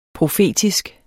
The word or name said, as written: PROFETISKE